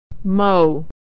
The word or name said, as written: mow